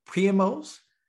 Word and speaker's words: Pre-MO: So big up to Sangram and the, how did you say it? Pre-MOs? – Pre-MO.